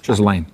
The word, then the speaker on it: Ghislaine: How do you say her first name? – Ghislaine. – Ghislaine. That's not how it's spelled.